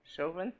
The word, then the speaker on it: Chauvin: Chauvin.